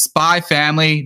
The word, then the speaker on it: Spy Family: uh, so the show we are talking about is Spy Family. The X is silent.